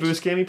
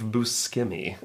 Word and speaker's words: Buscemi: And Steve Buscemi plays money drug guy number 3.